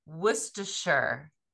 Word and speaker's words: Worcestershire: So it's Worcester, Worcestershire.